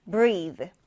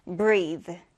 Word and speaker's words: breathe: 일단은 모음이 긴 I 발음이고요. 그리고 이때 TH 발음은 성대를 울리는 발음입니다. 그래서 breathe, breathe 이렇게 발음해 주면 되는데, breathe 하면 이제 동사로 숨 쉬다, 호흡하다라는 뜻이 되고요.